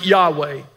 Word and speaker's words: Yahweh: We filled in the vowel points, so we pronounce it Yahweh.